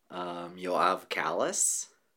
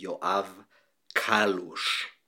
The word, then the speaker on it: Yoav Kallus: So in America, I usually hear Yoav Kallus. It's usually Yoav. People usually put the stress on the first syllable. But in Israel, it would be Yoav Kallus, which is more faithful to the Hungarian Kolus, I think would be in Hungarian.